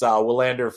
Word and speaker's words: Willander: I believe it's probably technically pronounced Vlander, but because this is America and we do that sort of thing, it's Willander for just for familiarity purposes for the audience more than anything else.